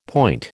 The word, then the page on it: point: American English